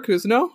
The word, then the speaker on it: Cousineau: Yeah, so my name is Laura Kuzno. Kuzno spelled C-O-U-S-I-N-E-A-U.